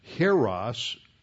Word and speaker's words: Hieros: Hieros, that is spelled H-I-E-R-O-S, H-I-E-R-O-S, and Naos, N-A-O-S.